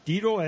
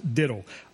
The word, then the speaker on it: diddle: That's pronounced diddle. I said deedle, which means skillful.